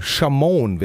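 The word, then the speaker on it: Shamone: Sie war auch ganz begeistert, als Michael Jackson, Chamon wird das ausgesprochen. Chamon, Chamon.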